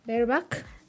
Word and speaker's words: bareback: Bareback